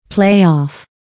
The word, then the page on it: playoff: pléɪɔ̀f; プレイオフ